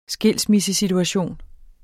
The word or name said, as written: skilsmissesituation